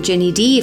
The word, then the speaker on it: Ginny D: It's Ginny like Ginny Weasley, G-I-N-N-Y, and then D like D-I, like Princess Di, but not pronounced like Di, because then it's Ginny Di, which sounds really aggressive.